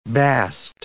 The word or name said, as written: bast